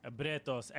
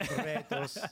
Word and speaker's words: Bretos: We are back with Max Bretos. And I did want to ask because... – That's the best pronunciation yet. – Bretos. – Bretos.